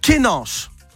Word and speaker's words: Guénange: Guénange, alors attention, apprenez à bien parler le Mosellan hein, dans le 54 et le 88. Ça s'écrit K-E-N-A-N-C-H-E. Guénange. Comme ça que ça se prononce.